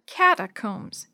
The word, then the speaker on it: catacombs: It said, Christians in the Catacombs. Dee Dee wrinkled her face in confusion. What's that word? Cata what? Catacombs, Mrs. Shue repeated slowly. The last part is pronounced combs, like combs to fix your hair.